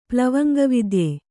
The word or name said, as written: ಪ್ಲವಂಗವಿದ್ಯೆ